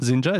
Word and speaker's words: Xinja: How would you pronounce Zinja. – It? Zinja, is that how you pronounce it? – Yeah.